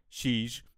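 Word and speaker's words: xis: You know how we pronounce X in Portuguese? – Sex? – No. Sheesh.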